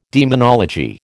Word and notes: demonology: American English

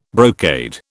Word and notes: brocade: British English

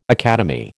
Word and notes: academy: American English

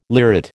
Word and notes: lyrate: American English

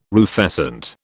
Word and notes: rufescent: British English

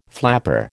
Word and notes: flapper: American English

